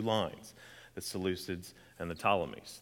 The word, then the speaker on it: Ptolemies: And these two dynasties come in the forms of two of them here, named the Seleucids and the Ptolemies. It's a silent P. So the Seleucids and the Ptolemies in verses 5 through 7.